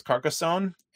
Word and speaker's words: Carcassonne: Béziers is spelled B-E-Z-I-E-R-S, Béziers. Gotta love the French language, man. B-E-Z-I-E-R-S. Carcassonne. Just spell carcass and then O-N-N-E.